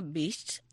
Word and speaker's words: bisht: Te lo voy a contar ahora, se llama Bist, y es Bist. Beast. – ¿Cómo se escribe? ¿Por qué? – B-I-S-H-T. Cuidado.